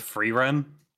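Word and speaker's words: Frieren: Am I pronouncing that right? Free Aaron? – I'm pretty sure it's Free Run. – I really don't know. Anyway, it was the one about the elf and... – You pronounce it Free Run in the show, so...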